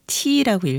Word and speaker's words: TEE: 오늘 저녁에 TEE 이거 T라고 읽어야 돼요.